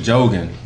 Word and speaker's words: Jigen: It's J-I-G-E-N. – Yeah. – Jigen. – But they pronounce it Jogen.